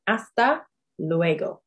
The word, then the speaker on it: hasta luego: So again, the H in hasta is silent. So hasta luego is see you later.